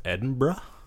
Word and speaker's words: Edinburgh: And then we took a trip out to Edinburgh, which I found out is pronounced Edinburgh. – Edinburgh. – Instead of Edinburgh. Borough? Borough? – Like, borough?